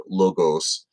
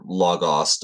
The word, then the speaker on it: logos: So I might interchange between logos and logos, just because I'm not going to push the, I was trained to speak with a very short O omicron, which is the O character in logos. So there isn't a long O there.